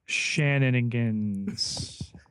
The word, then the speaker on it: Shannonigans: which the rather groan-inducing Shannonigans. – Shannon, yes, you said that correctly.